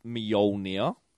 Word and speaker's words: Mjolnir: And Todd and Wade, it's pronounced Mjolnir.